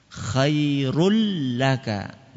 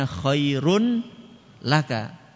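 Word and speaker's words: khairul laka (خير لك): Cara bacanya bagaimana? Idgham itu artinya dimasukkan. Apanya yang dimasukkan? Nun tanwinnya tadi dimasukkan ke huruf lam sehingga tanwinnya enggak kelihatan. Khairul laka. Enggak kelihatan kan? Bukan bacanya khairun laka.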